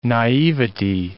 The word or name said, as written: naivety